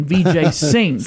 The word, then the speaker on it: Vijay Singh: Brooks, the correct answer and correct pronunciation, Vijay Singh.